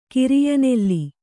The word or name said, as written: ಕಿರಿಯನೆಲ್ಲಿ